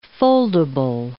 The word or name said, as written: foldable